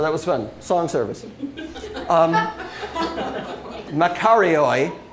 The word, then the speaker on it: makarioi: M-A-K-A-R-I-O-I in English letters. – It's Makarioi. Did I say that right? Okay, good. Because I know last time I tried to say it, it came out Macarena.